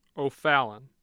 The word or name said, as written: ofallon